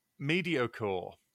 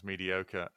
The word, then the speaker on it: mediocre: Which is how one of my friends still insists on pronouncing mediocre. only because he never heard it said out loud, and so thought it was pronounced mediocore. And then I said it's pronounced mediocre, and they're one of those people that when you tell them something, they assume that means you think they're a cunt.